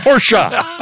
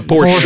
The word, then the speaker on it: Porsche: Porsche. I'm sorry, you have to say it right. It's a Porsche.